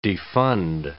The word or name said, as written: defund